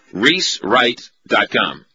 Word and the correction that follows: Weiss Wright: Is that right? – Weiss Wright. – Right. I knew I was gonna butcher it somehow.